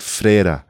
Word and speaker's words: Ferreira: Also das heißt eigentlich Ferreira, schreibt man es, aber man sagt Freira.